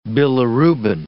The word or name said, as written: bilirubin